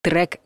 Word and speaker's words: трек: Еще одно слово в коллекцию пишем «е», но произносим «э». Треком называют песни в хип-хопе, и не только в нем. Но здесь главное произношение — трек.